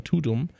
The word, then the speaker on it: Tudum: Und wahrscheinlich der Gag ist, dass es halt klingt wie Tadam. aber ausgesprochen im Deutschen klingt es wie Tudum. Und das ist natürlich nicht so schön.